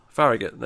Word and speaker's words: Farragut: Farragut. – Farragut, there you go, that's how you pronounce it.